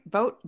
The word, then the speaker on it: Vogt: How do you say that? Steven, right? Vote. Is that how you say his name? – Yeah, Vogata.